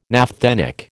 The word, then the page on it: naphthenic: American English